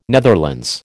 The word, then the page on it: Netherlands: American English